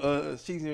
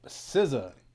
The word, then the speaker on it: SZA: What you think about Sheezy and green hair? You think she look good? – Who? – SZA. – In that green hair? You got me saying it wrong SZA.